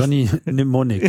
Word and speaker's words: Johnny Mnemonic: Johnny Mnemonic, ja. – Dankeschön. – Also das M spricht man eigentlich nicht.